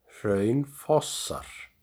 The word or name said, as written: Hraunfossar